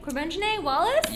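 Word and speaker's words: Quvenzhané Wallis: Corbengine Wallace? – Yes.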